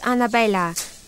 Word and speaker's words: Anabella: A-L-M-E-I-D-A. – And what's your first name? – Annabella. – Okay, Annabella. Here's your student card. – Thank you. Oh, my first name's wrong. – Oh, sorry. How do you spell it? – It's Annabella. A-N-A-B-E-L-L-A. – A-N-A-B-E-L-L-A.